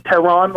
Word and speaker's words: Tehran: Find me on social atmosphere, Twitter, Instagram, Facebook, at I am Tehran, I-A-M-T-E-H-R-A-N. My name is Tehran, like the capital of Iran.